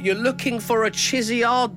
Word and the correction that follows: Charizard: Dave. I think that's one of the Pokemon goes. Charizard, right. Charizard.